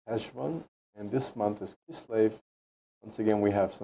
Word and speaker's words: Kislev: Did I pronounce that right? Kislev? – Yes.